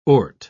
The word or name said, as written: ort